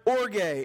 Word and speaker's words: orge: The word thymos and the word orge. And it is pronounced this way. It's spelled O-R-G-E. And I'm not just trying to be weird. It is pronounced that way.